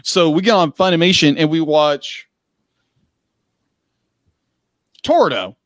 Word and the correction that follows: Toradora: It's Toradora. – Toradora. Thank you.